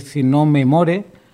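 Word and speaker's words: Sinnò me moro: Bueno, hemos escuchado anteriormente esta canción que antes pronuncié mal, es Si no me more, no en inglés como yo pensaba, sino en italiano, y es una obra pues compuesta por Janetti, Geruni y Rusticelli.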